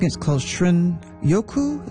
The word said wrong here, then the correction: shinrin-yoku: Is that how you pronounce it, the Japanese term for wood air bathing? – Shinrin-yoku.